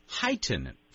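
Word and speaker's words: Hiten: It was renamed Heighton. Do you know, am I pronouncing that correctly? I hope I am. – Sure, that's exactly right.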